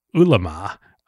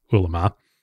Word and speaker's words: Ele.me: In fact, in the OG company in the food delivery space in China, a company called Ulema, which I think I'm saying that right. It is spelled ELE.ME. And this is going to become a very important player in the story, but I think it's pronounced Ulema.